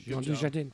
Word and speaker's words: Jean Dujardin: But yeah, Jean Dujardin, I think is how you say that. – That's how I said it. So I did great. – Jean Dujardin.